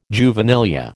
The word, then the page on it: juvenilia: American English